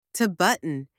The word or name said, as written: to button